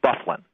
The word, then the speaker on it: Byfuglien: Well, it's spelled Byfuglien. That's how it's spelled, but it's pronounced Dustin Byfuglien.